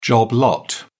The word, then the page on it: job lot: British English